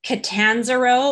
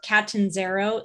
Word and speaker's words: Catanzaro: It's Catanzaro or Catanzaro, C-A-T-A-N-Z-A-R-O.